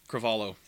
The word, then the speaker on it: Cravalho: Yeah, Alani Cravalho. – Yes. – I think I butchered her last name. I said it more Portuguese than Hawaiian.